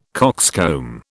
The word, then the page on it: coxcomb: British English